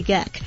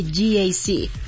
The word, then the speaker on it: GAC: No se dice GAC, se dice GAC.